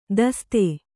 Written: ದೋಸ್ತ

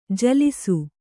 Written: ಜಲಸ

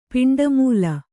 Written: ಪಿಂಡಮೂಲ

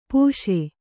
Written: ಪಾಶಿ

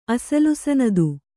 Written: ಅಸಲಸನದು

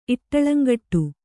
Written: ಇಟ್ಟಳಂಗಟ್ಟು

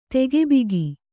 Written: ತಗಿಬಿಗಿ

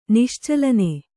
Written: ನಿಶ್ಚಲನೆ

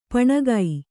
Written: ಪೌಣಿಗೆ

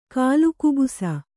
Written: ಕಾಲುಕುಬುಸ

